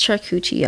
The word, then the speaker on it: charcuterie: It's charcuterie, y'all.